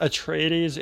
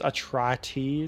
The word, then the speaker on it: Atreides: Not Atreides, it's Atrates. It's supposed to rhyme with atrocities.